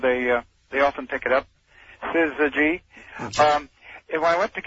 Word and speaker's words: syzygy: Oh, it sounds like there's an R in there. – Well, it shouldn't. Syzygy.